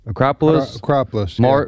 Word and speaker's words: Acropolis: The Acropolis. – Acropolis.